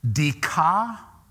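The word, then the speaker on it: Dikaiosuna (δικαιοσύνη): It's pronounced, like any of you really care, it'll just make me feel better. Dikaiosuna.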